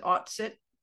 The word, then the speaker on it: Autsit: Autsit? – Yes, autsit.